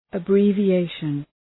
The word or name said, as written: abbreviation